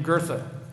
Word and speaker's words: Goethe: The quote was written 200 years ago by a German philosopher named Goethe. His name is spelled G-O-E-T-H-E, but for those of us in Texas, it rhymes with Bertha, as in Big Bertha.